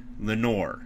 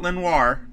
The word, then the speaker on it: Lenoir: Oregon has been hit maybe the most notably with Thomas Graham, Penei Sewell, and D'Amador Lenore, which is the first time I've pronounced his last name correctly on this show. Maybe the last time I say his name on this show, Lenore. Not Lenoir. I want to say Lenoir every time.